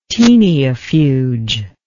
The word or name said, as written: teniafuge